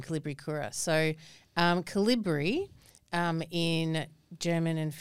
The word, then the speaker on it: Kolibricura: So the name is Kolibricura. Did I get that right? – Pretty much. Kolibricura, yes. It's pronounced however you want to really pronounce it.